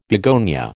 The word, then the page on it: begonia: American English